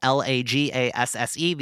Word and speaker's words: Lagasse: Funny enough, there's only two Christian Lagasses on Earth, Lagasse.